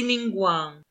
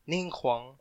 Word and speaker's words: Ningguang: Ay, si Lady Ningguang. – Yes. – Ningguak. Ningguakamole. – Diba ang proper pronunciation daw sa kanya, Ningguang? – Oh, Ningguang.